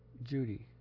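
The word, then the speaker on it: Cudi: And it's pronounced in Turkish as Judy.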